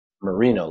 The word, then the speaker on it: Moreno: Bernie Moreno or Marino? – Marino, like Dan Marino.